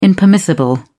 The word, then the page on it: impermissible: British English